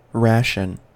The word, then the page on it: ration: American English